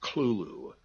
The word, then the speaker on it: Cthulhu: The U is about like that in full, and the first syllable is not unlike klul, K-L-U-L, in sound. Hence, the H represents the guttural thickness. S.T. Joshi, the great authority on Lovecraft, uses this pronunciation. He speaks of klulu.